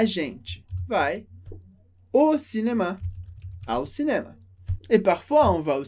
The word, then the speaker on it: cinéma: Pronúncia da palavra cinema com a sílaba mais forte, a última. Cinema.